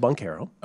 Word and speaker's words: Banchero: Paolo Banchero.